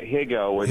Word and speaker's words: Higo: What about the story of Garrick Higo? Am I pronouncing that correctly? – Yeah, Higo.